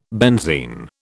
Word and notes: benzine: British English

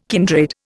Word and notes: kindred: Indian

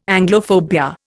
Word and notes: Anglophobia: Indian

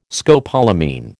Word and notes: scopolamine: American English

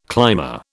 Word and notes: climber: British English

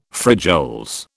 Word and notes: frijoles: British English

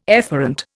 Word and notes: efferent: Indian

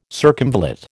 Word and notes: circumvallate: American English

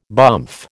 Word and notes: bumph: American English

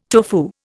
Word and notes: tofu: Indian

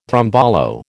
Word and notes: tombolo: American English

